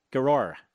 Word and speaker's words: Giroir: You pronounce it G-I-R-O-I-R, Grior.